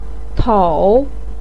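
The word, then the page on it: tong: tone 3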